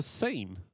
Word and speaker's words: seam: There were And then it turned out it was a seam. – Yeah. – Americans can't pronounce seam, they pronounce it sim.